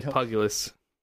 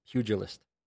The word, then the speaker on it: pugilist: It's a boxer, and it's pronounced pugilist. – Pugilist. – P-Y... O-O-J-E-L-E-S-T.